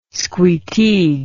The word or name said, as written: squeteague